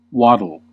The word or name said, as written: waddle